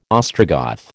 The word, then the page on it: Ostrogoth: American English